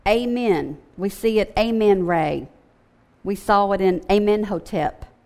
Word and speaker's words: Amen Ra: For example, Ihu, or who he, was the title of the one supreme deity, Ammon Ra, and that's actually, I think I've been saying that wrong, Ammon Ra. You can look at that, A-M-E-N, Amen Ra.